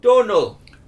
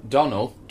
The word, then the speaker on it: Domhnall: Since Domhnall Gleeson's name will be coming up a lot with Star Wars 7 on the way, you should probably know that his first name is pronounced Donal. – Donal? – Donal. – Like donut.